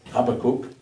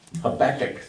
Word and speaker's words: Habakuk: Habakkuk, sagen wir auf Englisch, das ist schwer genug, Habakkuk.